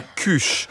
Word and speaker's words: kush: Bon, alors, ça s'écrit K-U-S-H. Moi, je dis cuche. Quand j'étais jeune, une cuche, c'était la queue de cheval des filles qu'on appelait comme ça.